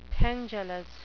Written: pendulous

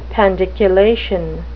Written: pandiculation

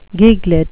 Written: giglet

